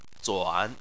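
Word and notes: zuan: tone 3

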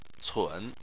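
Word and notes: cun: tone 3

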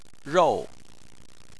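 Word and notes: rou: tone 4

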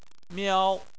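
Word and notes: miao: tone 1

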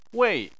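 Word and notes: wei: tone 4

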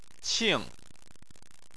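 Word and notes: qing: tone 4

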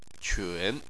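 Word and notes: qun: tone 3

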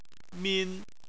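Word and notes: min: tone 1

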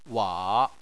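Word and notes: wa: tone 3

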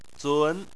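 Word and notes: zun: tone 2

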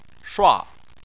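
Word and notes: shua: tone 4

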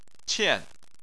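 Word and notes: qian: tone 4

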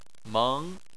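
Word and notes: meng: tone 2

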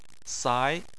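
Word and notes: sai: tone 2

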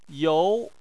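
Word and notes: you: tone 2

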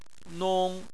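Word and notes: nong: tone 1

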